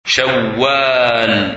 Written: Shawwal

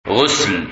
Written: Ghusl

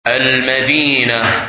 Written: Medina

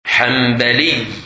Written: Hanbali

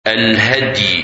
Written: Hady

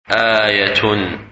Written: Ayah

